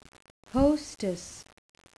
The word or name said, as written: hostess